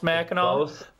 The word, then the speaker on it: Mackinac: It's both pronounced Mackinac, but they're spelled differently.